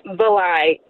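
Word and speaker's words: Varlai: It's V-A-R-L-A-I, but the R is silent.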